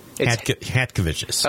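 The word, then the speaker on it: Chatkevicius: How do you pronounce the big man's name? Is it Chakovicious? – It's Hat. – Hat? – Yeah, Hat. Disregard the C. Hatkovicius.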